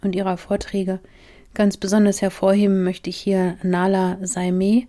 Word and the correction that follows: Nahla Saimeh: Ich glaube, korrekt wird sie Nachla Saime ausgesprochen. Eine absolute Koryphäe in dem Bereich der Kriminalpsychologie.